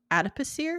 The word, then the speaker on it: adipocere: adipocere. Am I saying that right? – Mm-hmm.